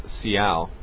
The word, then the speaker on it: Seattle: No, it's Seattle. – Oh, for God's sake. – That's the native indigenous pronunciation.